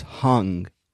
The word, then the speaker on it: Hang: The name of the instrument is hung. H-A-N-G, which in the Swiss Bernese language means hand.